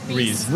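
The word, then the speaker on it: Riis: Am I saying it right? Rice or Reese Park? – Reese. – Reese. I'm glad.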